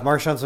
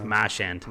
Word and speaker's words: Marchand: Marchand's been playing with Crosby. – You're saying his name wrong, by the way, to the Bostonians out of there. It's Marchand. – Marchand. – Marchand.